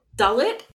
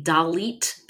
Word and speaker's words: Dalit: Dalit? – It's our secret word of the day! – And some people might say Dalit because that's how it's spelled in English,